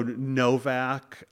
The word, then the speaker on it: Novak: Um, so Novak um,